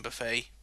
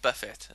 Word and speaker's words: Buffett: I think it's pronounced Buffett. – I don't know. I'm guessing if I had that many billions, I wouldn't really want to be referred to as Buffett. I'd rather be referred to as Buffet. – No, you said Buffet, is it? You know, and I think, you know. – Sorry, that's a mispronunciation.